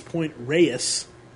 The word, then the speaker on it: Point Reyes: We said Point Reyes. It's Point Reyes, two syllables.